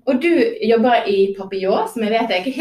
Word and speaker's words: papillon: Kan ikke vi den korrekte uttale, Rania? Hvordan sier vi det? – Papillon. Ja. Yes. Med den nasale lyden ja. På slutten.